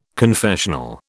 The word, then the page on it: confessional: British English